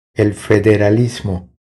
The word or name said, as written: El federalismo